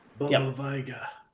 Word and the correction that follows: Baba Yaga: Yep, the Baba Yaga.